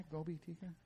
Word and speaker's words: gobi tikka: Did I pronounce that right? Gobi tikka? – Gobi tikka, right. Gobi tikka.